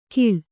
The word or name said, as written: ಘೀಳ್